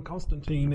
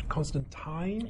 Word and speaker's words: Constantine: I once said Alan Moore, who wrote Hellblazer, he once said that it's not pronounced John Constantine, it's Constantine? – Yes, and it's also in the comic book that he says it's Constantine as well.